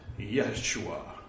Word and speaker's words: Yeshua: You know, it's not Jesus, it's not Jesus or Jesus, it's Yeshua, or however you would pronounce it with a Hebrew accent.